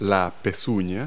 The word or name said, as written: hoof